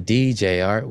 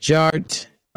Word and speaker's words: DJarTT: And my name is Jarrett, aka DJ Art, with two T's for a double dose of that tink tink. The D is silent, so it's just Jart.